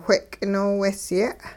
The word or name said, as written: whe'k'inoowehsye'